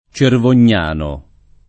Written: Cervognano

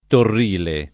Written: Torrile

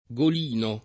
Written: golino

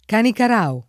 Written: Canicarao

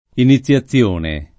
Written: iniziazione

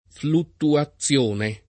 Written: fluttuazione

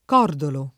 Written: cordolo

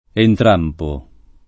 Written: Entrampo

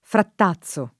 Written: frattazzo

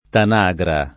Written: tanagra

